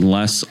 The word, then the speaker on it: Les: My personal Twitter is at Jason Less _. Last name is spelled L-E-S, J-A-S-O-N-L-E-S _.